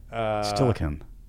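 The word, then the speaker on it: Steilacoom: And Stellacombe. How do you say it? – No, you got it.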